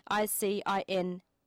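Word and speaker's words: Zingiber officinale: So it's Zingiber officinalis, Officinale, officinale.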